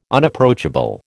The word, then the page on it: Unapproachable: American English